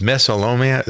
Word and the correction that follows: mesothelioma: How do you pronounce that? – Mesothelioma. Mesothelioma. – Right.